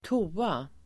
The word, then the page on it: toa: [²t'o:a]